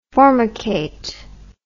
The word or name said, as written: formicate